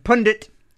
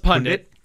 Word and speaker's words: pundit: And speaking of the scene magazine, Dean Young, our collaborator and pundit, who writes, I don't even know if I, did I say that right? – Well, it's pundit. – Pundit.